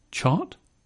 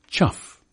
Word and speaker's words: chough: So how will you pronounce C-H-O-U-G-H? Like through? That is to say, chew? Like thought? Chot? Like cough? Chaff? Like though? Cho? Like bow? Chow? Like hiccup? Chop? Like enough? Chuff? Ah, seventh time lucky.